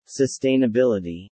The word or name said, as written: Sustainability